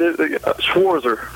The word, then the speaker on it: Schwarzer: Schwarzer. – Schwarzer, okay. They took the T out of there somewhere, okay.